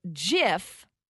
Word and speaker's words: JIF: So JIF, the peanut butter, is pronounced with a soft G.